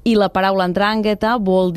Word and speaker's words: 'Ndrangheta: I per què se li diu 'Ndrangheta? Es diu, ho dic bé, per cert? Com ho diries? – Sí, es pronuncia 'Ndrangheta.